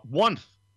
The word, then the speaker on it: Wunth: Wunth, it's pronounced W-U-N-T-H, but it's spelled at – the number one, THGOODS, on Instagram.